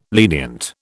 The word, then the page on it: lenient: British English